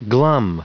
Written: glum

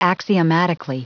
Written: axiomatically

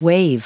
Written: waive

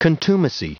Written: contumacy

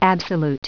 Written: absolute

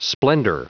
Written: splendor

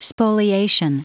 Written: spoliation

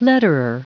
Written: letterer

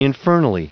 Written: infernally

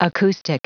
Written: acoustic

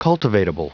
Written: cultivatable

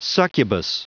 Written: succubus

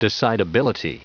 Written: decidability

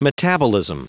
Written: metabolism

